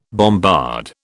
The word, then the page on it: bombard: British English